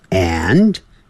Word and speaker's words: and: 好， And, A-N-D, 这个 A-N-D 啊，标准的念法是 and, 可是你实际念的时候是念成 n, n, 它念得很弱，甚至有时候念成 n, n, n, 那个的 A-N-D 那个的 n 的，它的那个的都不念出来了。